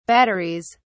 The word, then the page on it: batteries: British English